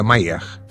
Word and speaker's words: Mayer: Em 1973, quando conheci o Parque Mayer em Lisboa, escreve-se Meyer com Y, se pronuncia Mayer.